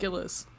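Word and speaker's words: Gillies: His name is Daniel Gilliam, I think, is how you pronounce Gillis? – It. – Gillis. – Gillis. Yeah, sorry.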